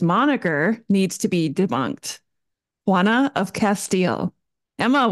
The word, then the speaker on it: Juana: Juana.